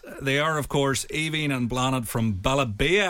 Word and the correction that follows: Be a Belle: How are you, ladies? – Hi, from Be a Belle. – Be a Belle, get the name right.